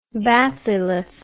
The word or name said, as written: bathylith